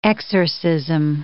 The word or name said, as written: exorcism